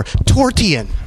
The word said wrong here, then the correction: Torsion: It's actually pronounced Torsion.